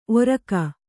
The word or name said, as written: ಓರಿಗೆ